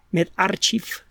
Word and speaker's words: medRxiv: El primero, le digo, prepublicado en MedArchiv, o MedArchiv, sería otra de las pronunciaciones del término, que repito, es MedRxiv, de chica.